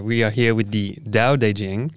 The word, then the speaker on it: Tao Te Ching: You see, so a lot of people mistakenly say like Tao, but it's actually pronounced with a D. It's Tao.